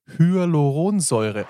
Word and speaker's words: Hyaluronsäure: Hyaluronsäure.